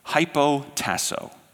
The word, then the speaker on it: hypotasso: So when that's transliterated, the English letters look and sound like this. Hypotasso, hypotasso.